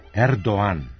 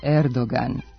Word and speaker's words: Erdoğan: Turski predsjednik je Erdoğan, a ne Erdoğan. Godinama smo njegovo prezime ispravno izgovarali, ali u pravopisu se to ne može provjeriti jer tamo transkripcije s turskog jezika nema. Oblik Erdoğan, koji su naši visoki zvaničnici nedavno uveli u jezik, zasnovan je na izvornom izgovoru tog prezimena u kojem se glas G ne čuje.